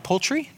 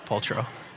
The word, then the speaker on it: Paltrow: Now, am I saying that right? Poultry? Not poultry, Paltrow.